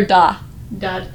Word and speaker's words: Navidad: So since you're the Spanish teacher, is it Feliz Navidad dot or da? – Dad. – Dad. Oh. With a D at the end.